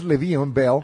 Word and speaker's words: Le'Veon Bell: No es Le'Veon, es Le'Veon Bell.